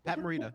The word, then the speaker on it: Pat Morita: Pat Morita.